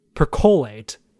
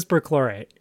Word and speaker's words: perchlorate: but Ethan Peck pronounces it percolate, but it is perchlorate, and I was really pleased by that.